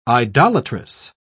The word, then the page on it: idolatress: British English